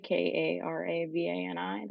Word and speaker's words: Karavani: And my name is spelled with a K, Karavani.